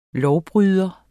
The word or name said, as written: lovbryder